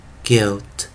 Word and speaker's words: guilt: 所以 U 啊，我们讲这个 U I 的时候，我们前面的 U 不发音，后面的 I 念一个短元哦。那我们这时候念 belt belt belt belt，或怎么念 elt guilt guilt guilt。